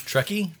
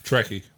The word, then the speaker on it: Truckee: Truckee? There's no other way to pronounce that. Truckee, California. – Truckee, right.